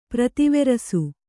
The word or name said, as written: ಪ್ರತಿವೆರಸು